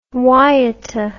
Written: waiata

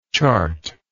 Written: chartable

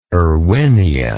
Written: erwinia

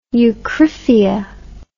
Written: eucryphia

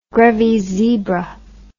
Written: grevys zebra

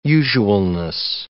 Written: usualness

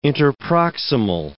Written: interproximal

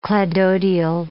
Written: cladodial

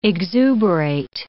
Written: exuberate

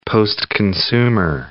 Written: postconsumer

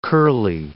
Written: curly

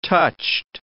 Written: touched

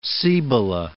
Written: Cibola

